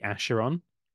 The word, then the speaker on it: Acheron: Right, so we learned how to say this together. This is the Acheron, because he turns things to ash with him being forever. – Acheron, like an idiot.